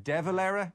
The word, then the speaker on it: De Valera: It's actually De Valera. We call him Dev.